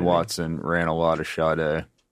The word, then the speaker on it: Sade: Is it pronounced Sadie? – Sade. Oh, my gosh.